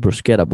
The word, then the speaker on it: bruschetta: It's called a bruschetta bowl, or actually I heard actually the proper pronunciation of bruschetta is bruschetta bowl.